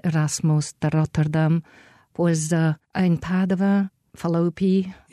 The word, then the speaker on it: Padova: Oh, but Padova is how you say it.